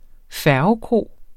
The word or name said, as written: færgekro